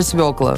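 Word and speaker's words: свёкла: Есть эта буква, ударение всегда падает на нее, так что запомнить, короче, свекла.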